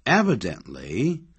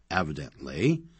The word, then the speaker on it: evidently: Evidently, this answer is right. 明显的，显然的，或者是似乎看上去是这个样子的。 Evidently, 那么不要念成 evidently 啊，它是一个副词，修饰整个句子。好，